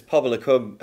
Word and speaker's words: Pobol y Cwm: It was, it's Pobol y Cwm. Uh, yes, but I always say it wrong. Say, say it again. Yeah. Pobol y Cwm. – Pobolicum, yeah.